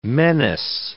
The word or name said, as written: menace